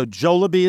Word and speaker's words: Jollibee: So Jollibee is the correct pronunciation of it? – Yes, and it's apparently a Filipino chain restaurant.